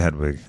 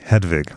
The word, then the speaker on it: Hedwig: Let's go back to Hedwig a little bit. Or sorry, pardon me. Hedwig, which I pronounced correctly for so many years. I don't know why I'm pronouncing it incorrectly in this show.